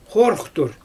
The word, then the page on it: xooruxtur: Xyzyl